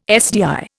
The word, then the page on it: SDI: Indian